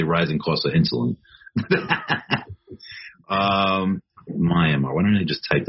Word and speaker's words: Myanmar: Myanmar, is that how you say it? – Mm-hmm. – Yes. Nice. Myanmar.